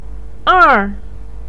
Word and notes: er4: pinyin

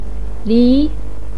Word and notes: li2: pinyin

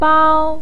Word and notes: bao1: pinyin

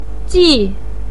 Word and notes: ji4: pinyin

